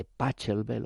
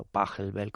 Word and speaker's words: Pachelbel: Hemos escuchado a la orquesta barroca St. Martin in the Fields, dirigida por Sir Neville Marriner, en este Canon de Pachelbel, o Pachelbel, como quieran pronunciarlo.